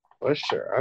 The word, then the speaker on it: Buescher: I'm going to go with Christopher Buescher again. – Buescher, okay. – I said his name right that time.